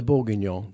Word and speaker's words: bourguignon: Oh, the bourguignon.